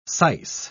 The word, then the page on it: syce: British English